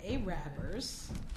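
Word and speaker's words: Arabber: The first one is like the A-rappers, because Baltimore's known for the A-rappers, spelled like Arabers, but it's pronounced A-rappers, but it's a Baltimore thing.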